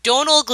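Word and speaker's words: Domhnall: It's Donal. The M is silent.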